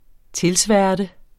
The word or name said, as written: tilsværte